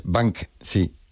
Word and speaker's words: Banksy: Pero bueno, como Banshee. – Esas palabras... Es Banksy.